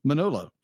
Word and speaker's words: Manolo: To quote, "I had always imagined the first film being about Menlor. Am I saying that right? – Manolo."